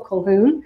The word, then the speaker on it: Colquhoun: The first sentence of the book is, it's pronounced Ithell, and it's Ithell Colquhoun.